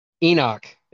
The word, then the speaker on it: Enoch: Did you say Epoch? – How do you say it? – Enoch. It's an N. Epoch is like a measurement of time.